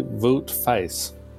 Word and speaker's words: Wout Faes: And to replace him, we brought in a defender I am informed is pronounced something like Wout Faes.